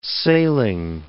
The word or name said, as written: sailing